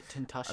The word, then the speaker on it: Tentacion: Tentacion. – Is that how you say it? Tentacion? – I think so. – Tentation? – Tentacion. – Tentacion.